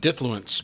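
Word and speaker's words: diffluence: Difluence. – Difluence. – Difluence. – Difluence. Yes, like difluent type of thing.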